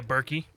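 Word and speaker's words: Birkie: It's Berkey, right? – Yeah, Berkey. – I'm saying that right, like Birkenstocks.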